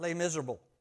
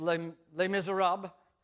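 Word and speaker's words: Les Misérables: But I had a lot of people talking to me about Les Miserables. I know it's not pronounced that way, Rochelle. It's Les Miserables, as she reminded me.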